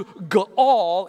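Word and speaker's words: ga'al (גאל): It's spelled G-A-A-L. G-A-A-L. It is the Hebrew word, and it's pronounced ga'al. Ga'al. That's a guttural.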